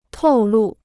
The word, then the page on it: 透露: tòu lù